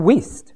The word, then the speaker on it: Wuest: A nice one too, but very difficult to read, is the West, or Worst, I'm not sure how he pronounces his name, W-U-E-S-T. And he's written this expanded version of the Bible, and it's very useful. W-U-E-S-T, West or Worst, or some. Anyone know how that's pronounced? West. Oh, Weast. Weast, I hadn't thought about that. Weast.